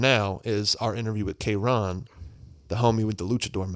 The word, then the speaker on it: Kayrxn: His name is spelled K-A-Y-R-X-N. – Yeah. – Say it like K-Ron. – K-Ron.